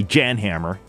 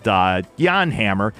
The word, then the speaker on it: Jan Hammer: Some people say Jan Hammer because that's the way it's spelled, but Jan Hammer.